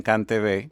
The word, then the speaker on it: CANTV: o creo que ustedes lo pronuncian CanTV. – CanTV, sí.